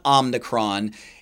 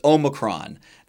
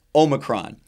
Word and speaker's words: Omicron: By the way, it's not Omicron. Study the Greek alphabet. It's Omicron. That is properly pronounced Omicron.